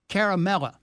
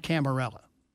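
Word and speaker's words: Ciaramella: Caramella, not Camarella. Yeah, I think I mispronounced it a couple of times.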